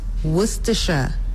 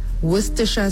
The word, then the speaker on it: Worcestershire: So it's an English condiment, and it's pronounced Worcestershire. Worcestershire sauce. Nie Worcestershire. – That's her shading me there at the end. By the way, nie Worcestershire. It's Worcestershire where I come from, okay?